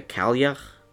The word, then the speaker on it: Cailleach: Okay, it's called the Cailleach, which that's kind of how it's pronounced. You're supposed to have the little sound at the end there that I made.